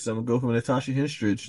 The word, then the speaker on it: Natasha Henstridge: I got mine based off the new character, Natasha Henstridge version.